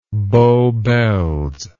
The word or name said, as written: bow bells